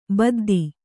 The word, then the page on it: ಬದ್ದಿ: baddi